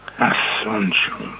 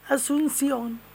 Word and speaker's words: Asunción: Just det, precis så. Det var nästan bättre att du uttalade det. Ja, faktiskt. Staden Asunción eller någonting ligger... – Det blev bara rummet. – Det stavas A-S-U-N-C-I-O-N. Asunción.